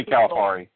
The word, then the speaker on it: Calipari: I think what it's definitely Calipari.